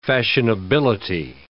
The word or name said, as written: fashionability